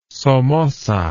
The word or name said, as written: somoza